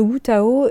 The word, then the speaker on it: Wutao: Alors le Wutao, et tu le prononces très bien, parce que c'est euh, avec un W, on ne sait pas toujours comment le dire, euh, effectivement, le prononcer en français.